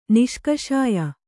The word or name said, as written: ನಿಷ್ಕಷಾಯ